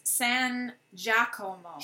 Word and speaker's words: San Giacomo: I'm probably mispronouncing that. – It is San Giacomo.